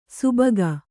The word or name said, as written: ಸುಭಗ